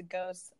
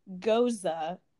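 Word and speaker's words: Gose: Like there's something called Here Goes Nothing, which is a ghost, obviously. Actually, a bartender taught me this weekend it's pronounced Goza. – Oh, interesting. – I'm not that bougie, so I'm probably still going to call it a ghost.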